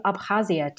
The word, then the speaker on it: Abkhazia: And so first off, I'm definitely not going to pronounce this correctly, but forgive me. What does the case study of Abkhazia tell us about your theory of partition? – Now, you pronounce it really well, so you have some hidden talents there.